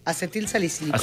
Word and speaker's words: acetilsalicílico: ¿Lo dije bien? – Acetilsalicílico. – Acetilsalicílico. – Exacto, eso.